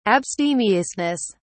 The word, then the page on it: abstemiousness: British English